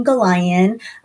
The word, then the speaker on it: Gollayan: Did I say it right? I've had her last name in my brain wrong for too long, and now I'm trying to relearn it. Christine, tell us a little bit about you. – Thank you. And thank you for having me and saying my name correctly, pronouncing my name correctly. Yes, Christine Galayan.